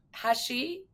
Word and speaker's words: Hachey: Good afternoon, Marg Hachey. Did I say it correctly? – Close, that's fine. – Okay, now you have to say it Hachey.